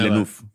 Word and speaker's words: Villeneuve: ¿Cómo se pronuncia? Villeneuve, Villeneuve, el, el, director de Dune, ¿no? Villanueva. – Villeneuve. – Villeneuve, ajá. Siempre se me va.